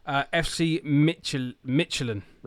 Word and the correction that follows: Midtjylland: How do I pronounce it? Educate me. – I think it's pronounced FC Midgeland.